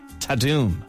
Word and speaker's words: ta-dum: So it's written ta-dum, but it's pronounced internally in Netflix ta-doom.